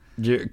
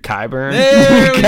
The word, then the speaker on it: Qyburn: Qyburn. – Qyburn. – I've been working on it. – Let's go. – Yeah. I was going to say Euron, but first you do have Qyburn Yes.